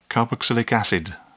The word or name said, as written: carboxylic acid